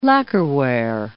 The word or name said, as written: lacquerware